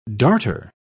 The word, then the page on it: darter: British English